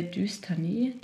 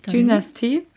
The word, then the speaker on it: Dynastie: Dystanie, Dystanie, sagt man das so? – Dynastie.